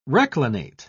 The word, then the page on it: reclinate: British English